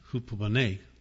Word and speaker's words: hupomone: I keep saying hupomone because that's how I first heard it. It's actually pronounced hupomone. But I'm not Greek, so.